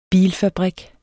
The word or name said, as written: BILFABRIK